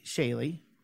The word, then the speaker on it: Chaille: It's spelled Chaille. Yeah. And I say Chaley.